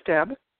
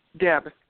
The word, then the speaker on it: Deb: My name is Deb. – Spell it for me. – Deb, D-E-B.